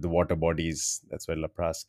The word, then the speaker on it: Laplace: Laplace, yes.